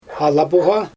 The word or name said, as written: ala boGa